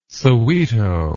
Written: soweto

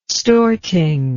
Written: Storting

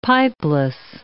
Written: pipeless

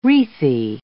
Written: wreathy

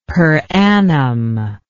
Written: per annum

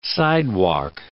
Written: sidewalk

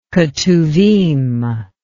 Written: ketuvim

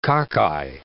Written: cockeye

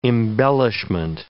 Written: embellishment

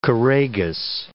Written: choragus

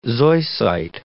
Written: zoisite

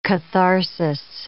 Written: catharsis

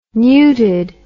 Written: newted

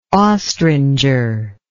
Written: ostringer

